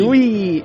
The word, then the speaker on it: Wii: ¡Wii! – En fin. – ¡Falta bien! – La mierda, Nintendo. – En palabras de la compañía japonesa Nintendo, se pronuncia como la palabra inglesa Wii, que significa nosotros.